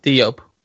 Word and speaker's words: Diop: Wait, sorry, how do you pronounce her name again? Sorry. – Diop. – And Dia, yes.